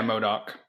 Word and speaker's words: MODOK: And then the Avengers are over here, like, fighting MODOK. Is that how you pronounce his name? – Yeah, MODOK.